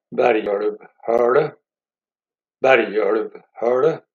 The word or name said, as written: Lillenysætra-Bergulvhullet